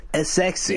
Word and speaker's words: ESXi: I can never pronounce that. – E S X I. As sexy. Yes. As sexy.